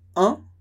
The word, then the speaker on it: un: Então, a pronúncia é un, masculino, e une, une, com o biquinho do início. De novo, un, e une, une, uma.